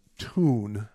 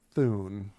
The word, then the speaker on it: Thun: And I've also been informed by my lovely wife that it is pronounced Tune, not Thune, even though it's spelled T-H-U-N.